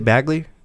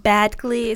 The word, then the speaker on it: Badgley: Is it Bagley? Is that how you pronounce it? – Badgley. It's D, then G. So, sure, let's go with Badgley.